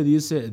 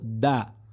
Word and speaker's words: the: Se pronuncia da seguido de consonante, por ejemplo, The cucumber. The cucumbers. Los pepinos. The potatoes. The potatoes. Las papas. Y se pronuncia D cuando va seguido de vocales.